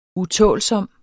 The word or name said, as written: utålsom